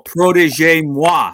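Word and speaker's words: Protège-moi: And I noticed that uh, it's going to be in a movie called Protege Moi. Am I saying that right? Yes.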